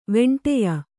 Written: ವೆಂಟೆಯ

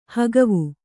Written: ಹಗವು

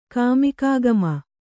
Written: ಕಾಮಿಕಾಗಮ

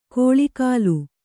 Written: ಕೌಳುಗೋಲ್

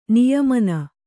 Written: ನಿಯಮನ

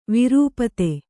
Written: ವಿರೂಪತೆ

